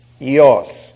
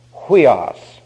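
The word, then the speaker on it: ios (υἱός): That's y-i-o-s, ios. Now, you'll hear it pronounced by those who are ignorant, huios, which is absolutely absurd. Huios sounds like something that my car does sometimes.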